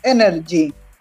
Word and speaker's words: NRG: que se pronuncia Energy, energía, que una caldera que tenía como esto, energía nuclear adentro suyo y la podía utilizar cuando él quería.